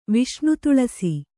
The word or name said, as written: ವಿಷ್ಣುತುಳಸಿ